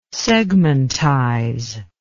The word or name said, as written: segmentize